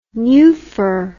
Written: nuphar

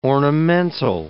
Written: ornamental

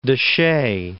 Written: Canyon de Chelly National Monument

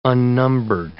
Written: unnumbered

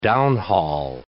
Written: downhaul

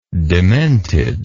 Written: dementedness

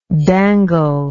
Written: danglingly

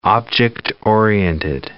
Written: object-oriented

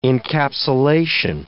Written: encapsulation

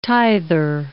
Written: tither

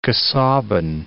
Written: Casaubon

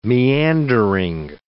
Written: meandering

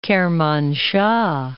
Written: Kermanshah

